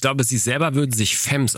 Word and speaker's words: Femmes: Ja, ich habe da wirklich sehr intensiv mal geforscht und die Lautschrift sagt Femmes. – Ja. – Also sie hat denselben Laut wie bei Violent. Dieses Femmes. Violent Femmes.